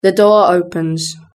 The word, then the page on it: the door opens: British English